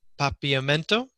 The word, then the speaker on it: Papiamento: She speaks five languages: English, Spanish, French, Dutch, and Papiamento.